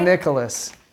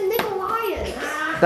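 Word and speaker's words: Nicolas: Nicolaias, please. Is that right? – Nicholas. Yes. That is correct. – I got six now. – He said it wrong. He said Nicolaias.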